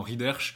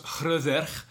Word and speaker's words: Hrøðr: Alors on écrit généralement Rider, chez nous-mêmes on a dit régulièrement Rider, mais en fait ça se prononce Hrøðr, dont il aurait tué, ou en tout cas dont il aurait conduit à la mort le fils et la fille.